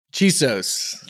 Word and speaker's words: Chisos: Am I saying that right? I am, right? Chisos? – Chisos.